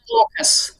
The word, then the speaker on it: AUKUS: We pronounce it AUKUS.